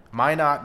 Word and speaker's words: Minot: Minot, North Carolina.